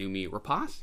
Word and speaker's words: Noomi Rapace: I like both Ethan Hawke and Noomi Rapace.